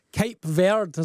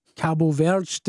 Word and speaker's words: Cabo Verde: Colombia beating Ghana by a goal to nil last night, and the world champion squeezing by Cape Verde, as I'm told to pronounce it, or Cabo Verde, depending whether you're saying it in English or Portuguese. My son speaks Portuguese and he's always correcting me on that.